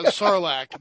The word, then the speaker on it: Sarlacc: Chewbacca gets swallowed by the Sarnak! – Sarnak? – Yeah, Sarlacc.